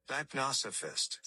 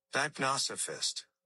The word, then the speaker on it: deipnosophist: D-E-I-P-N-O-S-O-P-H-I-S-T. How do you say it? – Dipnosophist. – Say it again. – Dipnosophist.